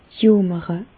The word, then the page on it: jomere: Bas-Rhin; Reichshoffen